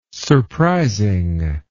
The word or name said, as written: surprising